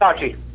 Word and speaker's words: Versace: Versace. That's correct. – Clearly, Scott isn't a fashionable person. – well, I wanted to think it was Versace.